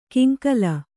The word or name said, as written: ಕಿಂಕಲ